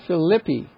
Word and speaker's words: Philippi: And from there to Philippi would be the correct pronunciation of that.